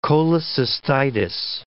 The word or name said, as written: cholecystitis